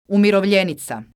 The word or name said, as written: umirovljenica